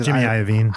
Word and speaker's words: Iovine: And his name is weird because it doesn't look like it should be Iovine. – Iovine. – Snoop called him Iovine, eating linguine. Like, yeah. So I was like, fuck, how do you say this? Is it Iovine? Is it Jimmy Iovine? – Iovine?